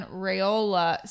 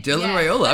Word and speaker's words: Raiola: And what percentage chance would you put on Raiola signing with us? Did I say that right? – You did. Dylan, I did. Dylan Raiola.